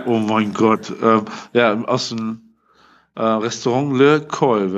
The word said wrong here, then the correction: Les Cols: wahrscheinlich wird es ausgesprochen. – Les Cols, ja.